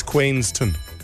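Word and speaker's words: Queenstown: Later, McLaren F1 car crashes near Queenston. Is that the correct pronunciation? Queenston. – It's Queenston. – Queenston. – Yeah, okay, Queenston. Cool.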